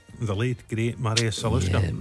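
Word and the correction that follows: Žaliukas: Zaliukas. – I'll let you away with the mispronunciation. Zaliukas.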